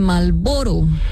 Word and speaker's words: Marlboro: Más ignorada que la R de Marlboro.